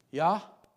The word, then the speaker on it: Yahweh: Yah, Quay. You see what I did? Yahweh. Yahweh.